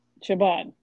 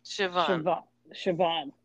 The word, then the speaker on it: Siobhan: Siobhan. No B. Siobhan.